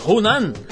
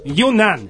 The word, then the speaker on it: Yunnan: A província de Hunan, Yahoo, da, do sul da China. Não foi muito bom esse, essa minha pronúncia. – Vai lá, vai lá. Né? A ah. província de Hunan. Yunnan.